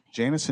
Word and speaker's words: Jannes: now is, how do you pronounce it? – Jannes and Jambres. – There you go.